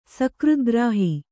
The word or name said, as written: ಸಕೃದ್ಗ್ರಾಹಿ